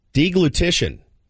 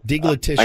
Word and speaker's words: deglutition: Deglutition. – You ever heard that one before? Deglutition.